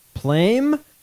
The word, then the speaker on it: Plame: Plame.